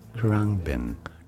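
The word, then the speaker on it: Khruangbin: Krungbin.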